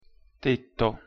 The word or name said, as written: tetto